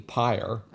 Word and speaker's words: pyre: Again, not one of these knee slappers, but it rhymes with fire appropriately, pyre.